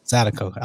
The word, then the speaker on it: zydeco: How you say it again? – Zydeco. – I was going to say Zydeco. But Zydeco? A Z? – Z-Y.